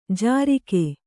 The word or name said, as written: ಝುರುಕಿ